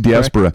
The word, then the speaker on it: Diaspora: It's not Diaspora. Am I pronouncing it correctly? Diaspora. – Correct. Yep.